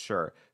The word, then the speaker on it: Shire: And when you're saying just the word S-H-I-R-E, it is pronounced Shire. But if it's at the end of a word, it is pronounced Sure.